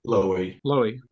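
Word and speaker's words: Loewy: How do you say his last name? Raymond Levy? – Lowy. – Raymond Lowy.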